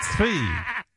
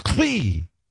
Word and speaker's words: Tzvi: You have to almost spit to say my name, Tzvi. It's like, wait, I got something caught in my throat. Svee. Svee. It sounds like feet. Sweet. Svee.